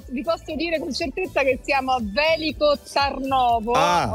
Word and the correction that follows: Veliko Tarnovo: Ho sbagliato sicuramente l'accento, perché è Veliko Tarnovo, però insomma noi qua ci divertiamo un po' a sbagliare tutti gli altri.